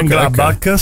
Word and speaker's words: Mönchengladbach: Di 22 anni che gioca nel Borussia Mönchengladbach, come si pronuncia, Mönchengladbach, sì.